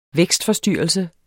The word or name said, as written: vækstforstyrrelse